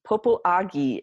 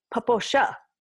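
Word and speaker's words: Popo Agie: Popoagi is how it looks like it's spelled. Popo, what? – P-O-P-O, Popo, Agi. – Popoagi. – A-G-I-E, but that is not how it's pronounced. It's Poposha, Poposha.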